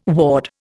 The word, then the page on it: ward: Indian